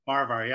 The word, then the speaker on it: Farahvar: Farahvar, yep.